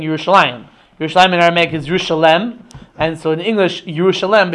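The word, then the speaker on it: Yerushalem (ירושלם): Actually, in the Aramaic parts of Tanakh, like in Daniel and in Ezra, so when it mentions the city of Yerushalayim, so so the vowelization there is as if the city is pronounced Yerushalem, which I think is probably the source of the English name for the city, Jerusalem, right?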